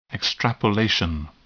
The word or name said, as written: extrapolation